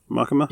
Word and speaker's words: Makima: Makima.